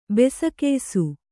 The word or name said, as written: ಬೆಸಕೆಯ್ಸು